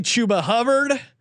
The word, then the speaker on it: Chuba Hubbard: I've been all over this guy to the point that I've been lectured on the pronunciation of his name. Give me Chuba Hubbard. Correctly nailed it there.